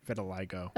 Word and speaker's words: vitiligo: I like how she mispronounced it. – Vitiligo? – Vitiligo. – Okay, I got it on the second try.